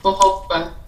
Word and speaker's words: jojoba: Oh, is it the J-O-J-O jojoba oil? Oh, wait. – Yes, J-O. That's how you pronounce it. Jojoba.